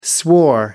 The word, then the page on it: swore: American English